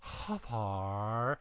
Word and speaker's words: Hfuhruhurr: It is spelled H-F-U-H-R-U-H-U-R-R. And you have to say it with that little pause, Huffar.